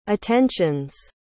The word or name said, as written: attentions